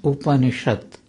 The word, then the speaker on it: Upanishad: Upa, Ni, Sat becomes Upanishad.